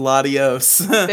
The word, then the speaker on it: Latios: Latias and Latios.